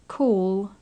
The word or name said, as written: call